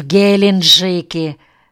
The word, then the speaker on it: Геленджике: «Вы на пенсии где жили?» «В Геленджике», — с ударением на первом слоге сказал Саяпин.